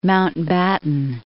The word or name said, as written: Mountbatten